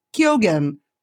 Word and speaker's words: Keoghan: It stars Dev Patel, Alicia Vikander, Joel Edgerton, Ralph Ineson, and I believe the pronunciation on this one, I worked very hard on it, is Barry Keoghan. That's the Irish.